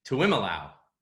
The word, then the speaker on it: Tuiamolau: JT Tuiamolau. How did I do? – Very good, Mike. That's was that right? – Tuiamolau. – Tuiamolau. JTT. You gotta say it like that though.